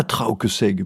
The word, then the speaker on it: Traouquesègue: Nous sommes bien à Trauqueseg, bien prononcées toutes les lettres, petite commune rurale dont le dernier commerce à fermer fut la station Barca.